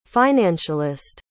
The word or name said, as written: financialist